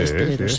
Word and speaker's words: esteleros: Dice Inma, en Canarias a los componedores de huesos se les llama esteleros. ¿Lo he dicho bien? Sí, sí, esteleros. Sí, estelero, sí. Sí.